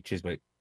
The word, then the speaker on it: Chiswick: Chiswick.